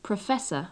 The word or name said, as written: professor